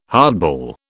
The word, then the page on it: hardball: British English